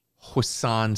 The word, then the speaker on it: hoisin: hoisin sauce.